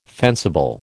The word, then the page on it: fencible: American English